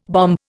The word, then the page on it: bombe: Indian